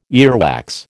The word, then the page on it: earwax: American English